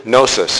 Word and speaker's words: gnosis: The Greek word gnosis, spelled with a G, G-N-O-S-I-S, is translated into English more or less as knowledge, as in things that we can come to know through study, through investigation.